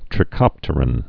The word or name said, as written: trichopteran